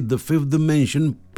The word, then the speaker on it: The 5th Dimension: Estoy hablando de The Fifth Dimension. Y lo pronuncio así, The Fifth Dimension, porque los nombres propios generalmente, por regla gramatical, no se traducen.